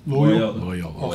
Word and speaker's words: Loyola: How do you say it? Loyola? – Loyola. – Okay, Loyola.